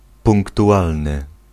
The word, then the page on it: exact: France